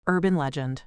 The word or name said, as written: urban legend